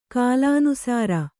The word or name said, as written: ಕಾಲಾನುಸಾರ